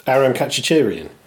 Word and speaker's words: Aram Khachaturian: Aram Khachaturian. Yeah, I'm not going to do any better than that. – Aram Khachaturian? – That's the one, yes.